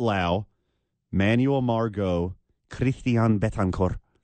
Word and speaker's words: Lowe: It's spelt low, but it's pronounced Lau.